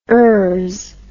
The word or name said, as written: ers